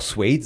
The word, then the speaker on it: Suede: Ja, du hast Suede, man kann auch Suede sagen. – Ja, ich wollte gerade sagen, du hast es annähernd richtig ausgesprochen.